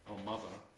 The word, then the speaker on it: Almaza: Almaza, am I saying that right? Yes, Almaza beer.